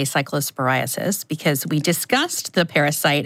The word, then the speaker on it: cyclosporiasis: If you are worried, there is a CDC cyclosporiasis, I didn't pronounce that right, outbreak page. Cyclosporiasis, yes.